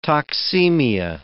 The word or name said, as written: toxemia